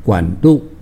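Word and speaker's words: 管辂: 大家讲的是一个算命师，叫做管路。 – 管路，电线的管路吗？ – 这个管就管子的管，管仲的管。路呢比较难写一点，那是车字边。 – 车字边。 – 对，然后右手边一个个。 – 嗯，左边是一个车子的车，右边是一个个字为证的个。 – 对，那这个是读作路，管路。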